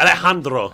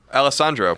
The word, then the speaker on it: Alessandro: Alejandro. – Right? No, it's got two S's. So Alessandro. – Alessandro.